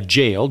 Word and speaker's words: gaol: Interesting, I'm just remembering, I'm not remembering, I'm just thinking to myself now, uh, as we mentioned the hangman, uh, G- uh, jail, G A O L, right, pronounced like so,